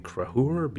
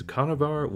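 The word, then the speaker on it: Conchobar: Also, heads up for anyone who does know these stories, I'm using the name Conor instead of the actual name Crahur because I learned to pronounce the name as Conavar, which is apparently wrong, and I'm sorry, Ireland, but I just don't like the pronunciation Crahur.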